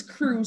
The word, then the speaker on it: cru: And it's crew spelled C-R-U, which is like the crew level in France.